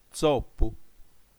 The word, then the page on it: tzopu: tsó.pu; ['tsop:u]